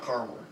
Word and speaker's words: Carmel: It says caramel is pronounced with 3 syllables. Caramel is a city pronounced with 2 syllables. – Caramel Mountain. – Yeah. – What am I going for? – That's spelled C-A-R-M-E-L.